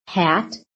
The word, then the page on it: HAT: American English